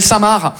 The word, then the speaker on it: Saint-Marc: On est d'accord, les Français, que Saint-Marc, ça s'écrit M-A-R-C, comme le prénom Marc. Mais vous, vous mettez un saint devant le prénom Marc, le C, il est parti en grève.